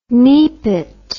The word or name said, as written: nepit